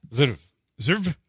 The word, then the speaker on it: Zverev: Zverev.